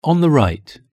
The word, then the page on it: on the right: British English